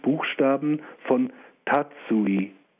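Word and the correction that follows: tacui: oder nein, falsch ausgesprochen, Takui, ich habe geschwiegen, die den Fundort offenbarten, als man ihn in die Moldau geworfen hatte.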